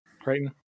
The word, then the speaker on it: Crichton: And this movie is directed by Steven Spielberg with a screenplay by Michael Crichton. Did I pronounce that right? – Yep. – Crichton.